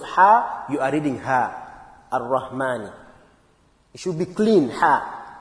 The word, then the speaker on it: Ar-Rahman: The second verse, Ar-Rahman Ar-Rahim. In Ar-Rahman Ar-Rahim, one very common mistake is the pronunciation of Ha. Again, we mentioned it already in in the first verse in Alhamdu. Ar-Rahman Ar-Rahim, Ar-Rahman Ar-Rahim.